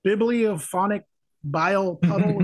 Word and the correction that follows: Bovinophobic Bile Puddle: Am I pronouncing that right? – I think it's a Bovinophobic Bile Puddle. – Bovinophobic Bile Puddle. That's correct, right? – I believe so.